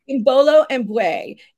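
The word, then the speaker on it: Imbolo Mbue: Tell me about Imbola. – Imbolo Mbui is how you say her name.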